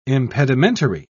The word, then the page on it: impedimentary: British English